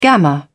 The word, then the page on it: gamma: British English